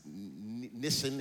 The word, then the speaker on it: Nisan: I think it's Nisan is how you say it.